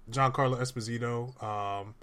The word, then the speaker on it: Giancarlo Esposito: Now we got Giancarlo Esposito. Did I say his name right? I think I did say his name right. We had Giancarlo Esposito playing the antagonist in this.